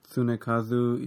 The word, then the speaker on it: Tsunekazu: Tsunekazu Ishihara? – Ishihara, I thought I could do. It's like Tsunami. – Yeah, his name's Tsunami? – No, it's like the same pronunciation.